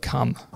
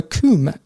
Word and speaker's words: Cwm: Now, the Welsh mine village of Cum, what do you think? – That one's fake. – That is fake, yes. So Cwm, C-W-M, is how it's pronounced in Welsh.